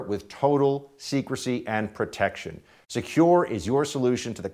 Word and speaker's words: Sekur: That's why you need a new privacy and cybersecurity application tool called Secure. It's spelled S-E-K-U-R.